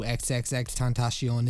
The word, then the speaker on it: XXXTentacion: XXX Tentacion is. The nom de rap is pronounced XXX tentacion, as in the Spanish word for temptation.